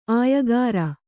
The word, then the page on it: ಆಯಗಾಱ: āyagāra